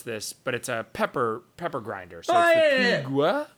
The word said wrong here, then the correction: Peugeot: P-E-U-G-E-O-T. Do you want Peugeot. – To pronounce that? – That's Peugeot.